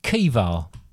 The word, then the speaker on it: Caoimhe: Right. C-A-O-I-M-H-E. How would you pronounce that? – C-A-O-I-M-H-E. – Yep. – This one's a hard one. Craig. – Craig? It's actually pronounced Kiva.